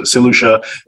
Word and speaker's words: St. Lucia: So St. Lucia, for those who, who don't know how it's pronounced, so St. Lucia is one of five territories or five jurisdictions in, in the Caribbean which offer citizenship by investment.